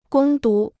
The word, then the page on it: 攻读: gōng dú